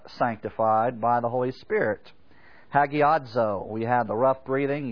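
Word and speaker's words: hagiadzo (ἁγιάζω): And it's spelled H-A-G-I-A-Z-O, hagiadzo.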